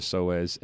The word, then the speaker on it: psoas: It's spelled P-S-O-A-S, psoas. – Not like Suez Canal.